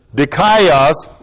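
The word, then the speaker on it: dikaios (δίκαιος): D-I-K-A-I-O-S-U-N, long E, dikaiosune.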